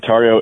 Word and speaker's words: Tario: Tario, Tario.